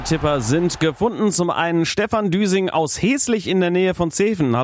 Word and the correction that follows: Heslingen: Heslich habe ich richtig gesagt und das ist wie gesagt in der Nähe von Zeven, richtig? – Ja, also Heslingen.